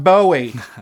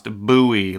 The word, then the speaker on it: Bowie: It's not Bowie. – It is pronounced Bowie, like boo, Halloween time, Bowie. – Or like a buoy on the water that's, for whatever reason, spelled B-O-U-Y or whatever.